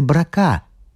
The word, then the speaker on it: Брока: Подобные истории очень любят и жители улицы Брока. Есть в Париже такая улица. Произносить ее надо с ударением на букве «А».